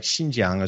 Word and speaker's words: Xinjiang: Or Xinjiang, which is another X-I-A-N-G, like Xinjiang is where you're talking about with the Uyghurs out there. So anytime you see an X in Chinese, you pronounce it as an S in English language.